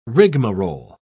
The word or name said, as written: rigmarole